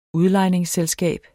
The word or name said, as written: udlejningsselskab